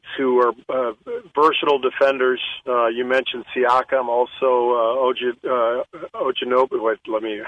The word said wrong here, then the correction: OG Anunoby: How do I pronounce his name? – OG Anunoby.